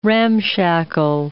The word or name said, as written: ramshackle